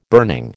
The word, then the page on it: burning: American English